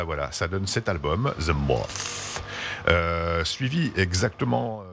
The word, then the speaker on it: The Moth: Ça se prononce comme ça, The Moth. The Moth. – Non, c'est bien, The Moth. – Oh, the Moth. The Moth. – Le TH est parfaitement prononcé, bravo.